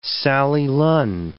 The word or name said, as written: Sally Lunn